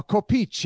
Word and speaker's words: coppice: I believe it's pronounced a copice.